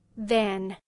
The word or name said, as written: than